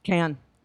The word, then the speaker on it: Cannes: How do you pronounce Cannes? – Cannes.